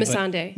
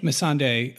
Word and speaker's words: Missandei: Missandei. – Missandei,